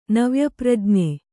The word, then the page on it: ನವ್ಯಪ್ರಜ್ಞೆ: navya prajṇe